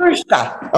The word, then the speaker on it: Petruska: Petruska.